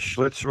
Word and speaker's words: Schlitz: Yeah, I think it was Schlitz rhymes with you know what.